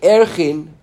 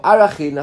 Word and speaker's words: Arachin: Mid itzrich lemichtav umale gabei erchin or arachin. I think is the rabbi they pronounce it these days, right? Yeah. Arachin, right? Okay. Right. It says arachin.